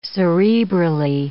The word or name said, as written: cerebrally